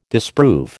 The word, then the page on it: disprove: American English